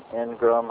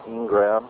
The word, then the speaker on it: engram: The first one we call an engram or ingram. It's spelled E-N-G-R-A-M.